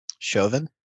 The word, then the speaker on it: Chauvin: So in about 40 minutes, Alf, Christopher Maddox, and Parrish are going to talk about the guilty conviction of the Chavanaugh trial. So be here for that. Everybody knows that Alf is as... – Did you just call him Chavanaugh? – How do I say his name? – Chauvin.